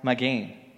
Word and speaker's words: magen: This noun in Hebrew is pronounced magen.